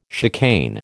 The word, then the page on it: chicane: American English